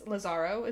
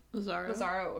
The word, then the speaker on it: Lázaro: There is a president who wanted to make a lot of reform named, I'm going to botch this, Lázaro is how you say it, I believe. – Lázaro, okay.